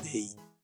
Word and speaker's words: they: T-H-E-Y. Mas a pronúncia é dei.